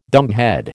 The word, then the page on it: dumbhead: American English